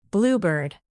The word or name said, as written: bluebird